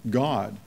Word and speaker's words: Gad: The Hebrew letters for the deity of fortune called God is Gimel Dalet, pronounced God, G-A-W-D, according to Strong's, as we know as Gad, as it's been adjusted.